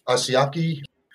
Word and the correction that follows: Hajime Asaoka: you know, the guy who's self-taught. – Yeah, Hajime Asaoka.